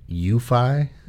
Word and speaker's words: Eufy: UFI? – That is correct.